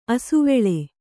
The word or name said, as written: ಅಸವಳಿ